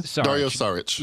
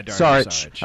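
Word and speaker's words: Dario Šarić: Dario Saric. – Yeah, Dario Saric.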